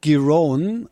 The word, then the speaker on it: Giron: Runde, weiß nicht, ob ich den Namen richtig ausgesprochen habe, aber das weiß Matthias Kammann sicherlich besser. Wie sieht's denn aus für den Hamburger? – Ja, er spricht sich tatsächlich Marcus Giron und Alexander Zverev tut sich bisher so leicht, wie man das erwartet hat.